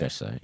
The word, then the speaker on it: Cheshire: We're Chestershire. – Yeah. Cheshire, yeah. – That's how you pronounce it.